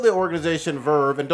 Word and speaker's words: VRV: My dumbass did not know this, but it is pronounced Verve. – Oh, I don't think anybody knew that. – Not VRV.